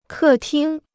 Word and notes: 客厅: kè tīng

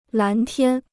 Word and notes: 蓝天: lán tiān

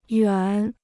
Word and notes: 远: yuàn

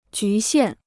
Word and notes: 局限: jú xiàn

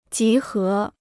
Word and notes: 集合: jí hé

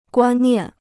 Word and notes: 观念: guān niàn

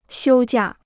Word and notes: 休假: xiū jià

